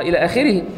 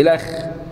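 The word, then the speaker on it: إلخ: الف لام خاء دي اصلها الى اخره. فده لما يكتب الى اخره يقوم كاتب لك الاخ. فاحنا قلنا كل النحت ده اسمه نحت. كل النحت الخطي ده ينطق اصله. يعني ما ينفعش تقول ايه الاخ.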